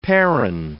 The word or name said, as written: perron